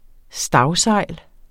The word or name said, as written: stagsejl